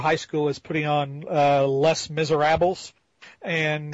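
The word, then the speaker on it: Les Misérables: It's Les Miserables.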